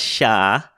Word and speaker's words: chá: Yeah, in Portuguese, it's pronounced chá. Because of Macau. They got their tea from Macau, which is a Cantonese speaking region. And so, yeah, they kept that. But the rest of Europe, the rest of Western Europe calls it tea or te. But Portugal or Brazil is the only one who uses the chow pronunciation.